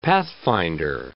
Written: pathfinder

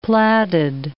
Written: plaided

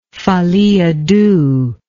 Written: folie a deux

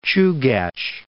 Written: Chugach Mountains

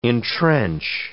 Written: intrench

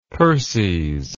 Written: perses

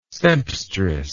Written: sempstress

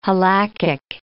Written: halachic

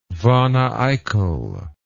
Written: wanneeickel

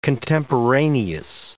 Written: contemporaneous

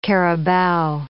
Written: carabao

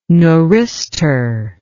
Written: neuristor

